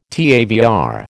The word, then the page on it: TAVR: American English